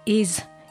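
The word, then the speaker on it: Ys: There was the Milk-Eyed Mender in 2004, Is in 2006. – Ease. – It is pronounced Is. – It's is. – How is it spelled? – It's spelled Y-S. – But it's pronounced is.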